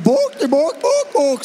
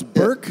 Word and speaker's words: Bourke: It's on the corner of Swanson and Bork Street. – How would you say it? – How Burke? Do you say B-O? Bloody Burke, mate. You gotta ignore that fucking O in there.